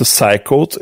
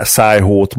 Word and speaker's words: Psycho: Psych, psycho, inkább psycho, ez szerintem közelebb áll a kiejtés, az amerikai kiejtés, ez mindenképp.